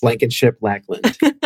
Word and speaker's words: Blankenship-Lackland: Blankenship Lackland.